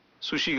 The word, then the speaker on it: سوشی: سوشی کا درست تلفظ یوں ہے سوشی.